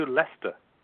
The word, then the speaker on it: Leicester: Keep going. Eventually you'll get to Leicester.